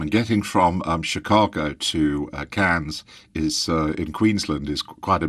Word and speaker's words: Cairns: He's just come back from Australia to the Australian Tourism Exchange in what I used to pronounce as Cairns, but I believe you pronounce it Cairns.